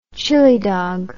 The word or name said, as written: chili dog